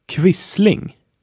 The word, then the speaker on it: Quisling: In addition, you will find it in front of a U or a V, and it's always pronounced like a K. There is only one word under Q, Quisling.